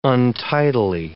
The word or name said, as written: untidily